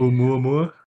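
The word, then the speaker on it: 'Oumuamua: So, Harvard astrophysicist Avi Loeb, I believe is how you say their name, believes that the Oumuamua, BJ, correct me, is that how you say that? – I think you're good. Oumuamua? – Yeah.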